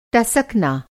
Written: टसकना

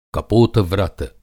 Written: कपोत-व्रत

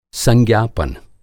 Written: संज्ञापन

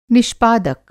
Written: निष्पादक

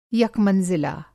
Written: यकमंज़िला

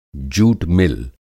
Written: जूट मिल

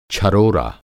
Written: छरोरा

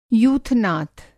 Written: यूथनाथ

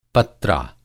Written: पत्रा

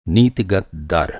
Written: नीतिगत दर

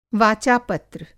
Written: वाचा-पत्र

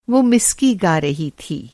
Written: मिस्की

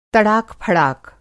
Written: तड़ाक-फड़ाक

